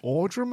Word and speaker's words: Audra: Audra McDonald? – Mm. – No, I do not. What kind of a name is Audra? – Well, A-U-D-R-A. I'm pronouncing it Audra McDonald.